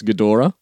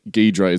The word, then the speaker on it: Ghidorah: I'm saying it's Ghidorah. I'm fairly certain that's the classic original Japanese pronunciation. And I think Ghidra is very much an Americanization of the same name.